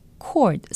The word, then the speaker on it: cord: Cord, C-O-R-D, cord, R 발음을 안 하고 그냥 코드라고들 말씀 많이 하시는데요. cord, 전기선, 전기코드, 끈, 발음은 cord입니다.